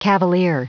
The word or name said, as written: cavalier